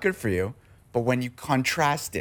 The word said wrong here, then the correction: contrast: it, I don't know why I said contrast like that. – When you contrast it. – Yes, I understand. – Emphasis on the wrong syllable.